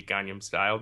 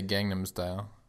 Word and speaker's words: Gangnam Style: If they're recognizing a music video from 2012, we don't want it to be Gangnam Style, do we? – No. Oh, good pronunciation. I just say Gangnam Style.